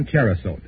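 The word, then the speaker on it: Cherosote: You can depend on Cherisote. It's spelled CHERROSOTE.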